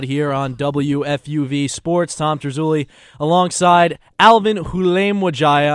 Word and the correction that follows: Halimwajaya: Halimwajaya, not Hulemwajaya. So I was pretty close.